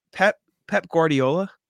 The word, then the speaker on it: Pep Guardiola: Is it Pep Guardiola? Did I say that right? – Yeah, you're correct.